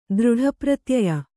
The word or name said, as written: ದೃಢಪ್ರತ್ಯಯ